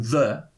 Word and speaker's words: ye: Maar dat, dat, dat is gewoon the. Dat mensen die dat eh, eh, als ye uitspreken, die, die zeggen het verkeerd. Het moet gewoon the zijn, want dat is geen ei, maar een thorn. Die lijkt op een ei.